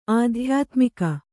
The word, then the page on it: ಅಧ್ಯಾತ್ಮಕ: Kannada